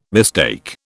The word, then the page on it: mistake: British English